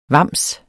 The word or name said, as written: vams